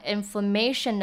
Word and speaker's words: inflammation: 再我们来看 inflammatory 呢，指的是激动的或者是发炎的，因为 inflammation 指的是发炎。像是说 she did not take care of her wounds carefully like the doctor instructed and now she has an inflammation，她没有好好照顾她的伤口，所以她现在伤口已经发炎了。那 inflammatory 的发音呢，它的 MA 的 A 就发短音而 inflammatory，那可是如果讲到 inflammation 的话呢，重音是在 mation 这个地方，那 fla 是发短音鹅的音， inflammation 跟 inflammatory 重音不太一样。